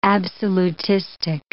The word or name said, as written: absolutistic